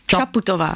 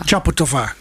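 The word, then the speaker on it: Čaputová: En ook, jij spreekt het anders uit dan ik. Ik wou zeggen Chaputova, maar Chaputova. – Chaputova, klemtoon. – Op de eerste.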